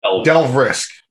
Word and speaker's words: Delve Risk: Delve Risk.